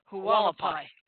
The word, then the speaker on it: Hualapai: That's pronounced Hualapai.